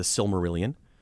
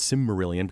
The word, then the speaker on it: Silmarillion: There's the Silmarillion, which for a long time I would pronounce Simmarillion because I didn't know there was a first L in there somewhere along the line.